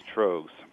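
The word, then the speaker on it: Tröegs: And what did you mention? Trogs? T-R-O? Spell it for me. – T-R-O-E-G-S. Trogs.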